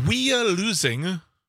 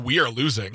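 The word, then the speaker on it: Wyalusing: We are losing State Park. sounds like we are losing.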